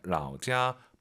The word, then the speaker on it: 老家: 삼성일성은요, 선생님을 떠올리세요. 老师 발음하고 똑같잖아요. 老家. 발음이 똑같은 게 아니고 성조가 똑같은 거죠.